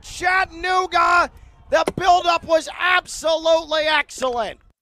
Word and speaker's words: Chattanooga: And I just want to put it on record, League One, Chattanooga, four syllables. Four syllables.